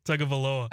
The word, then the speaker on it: Tagovailoa: Tagovailoa. – I don't think Chad said it right. – Tagovailoa.